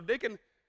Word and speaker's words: Nacon: It's like Bacon, but it's Nacon. So Nacon, they get to Nacon's property. Now, for a minute, just think about this. Do you think they called and warned Nacon?